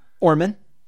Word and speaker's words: Orman: Orman.